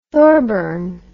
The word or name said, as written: thorburn